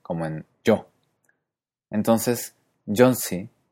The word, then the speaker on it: Jónsi: En islandés, cuando la I lleva acento, se pronuncia I como en español, pero cuando no lo lleva, como en Jónsi, se pronuncia similar a la E cerrada del francés I, como en mi, míos. I.